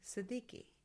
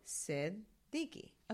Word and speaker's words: Siddiqui: And then Siddiqui. – Mm-hmm. Siddiqui. Yeah. – The Q, you know, throws a little bit people off and the double D's, but it's Siddiqui.